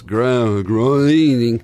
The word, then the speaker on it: Groening: It's Groening.